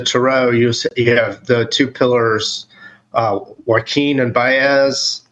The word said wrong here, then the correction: Boaz: I'm not saying that right, but Boaz and Joaquin.